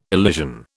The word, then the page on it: elision: British English